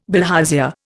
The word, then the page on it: bilharzia: Indian